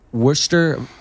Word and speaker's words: Worcester: So you are originally, you're originally from New York, but you actually live out in Worcester, Vermont. – That's right, you got it. – I said it right.